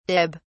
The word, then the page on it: dib: British English